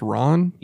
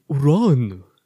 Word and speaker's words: Ran: So it's pronounced Ron? – Yes. It's spelled R-A-N, but it's Japanese, so yeah. – How's it pronounced, really? – Ron.